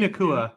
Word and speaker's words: Nacua: Puka Nacua.